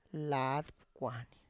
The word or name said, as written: laad pkwany